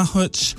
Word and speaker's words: Hutch: You know who I'm referring to, H-U-T-C-H. Is that how you Hutch? – Yes.